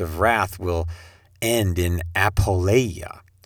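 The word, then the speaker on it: Apoleia (ἀπώλεια): is the destination is I can get my Greek pronunciation so messed up. Sorry about that. It's Apoleia. Apoleia.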